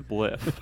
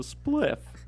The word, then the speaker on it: spliff: Which was weird, because I would just see people smoking a bliff. – It's pronounced spliff. What the fuck is a bliff? – This is my knowledge of the marijuana scene. I have none. – It's a spliff.